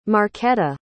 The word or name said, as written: marquetta